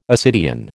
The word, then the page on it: ascidian: American English